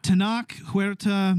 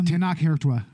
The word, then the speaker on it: Tenoch Huerta: Tenoch Huerta. Tenoch Huerta.